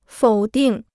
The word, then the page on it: 否定: fǒu dìng